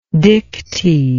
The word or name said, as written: dicty